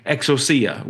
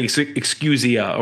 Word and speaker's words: exousia: And so that word power, or that word dominion in English, is in Greek exousia, which is or some say excusia, or there's different ways you can pronounce it, but it means authority, like the right to rule, the right.